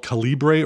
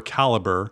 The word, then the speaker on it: Calibre: Uh, turns out there's an open source program uh, called Calibre or Caliber, yeah. depending on how you want to pronounce it.